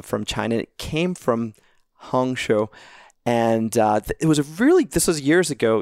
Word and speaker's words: Hangzhou: Nanjing, Wuxi, Hangzhou. I hope I'm pronouncing all these right. Emily can correct me. – You did a good job. Hangzhou, but close. – Okay. Hangzhou? – Yes.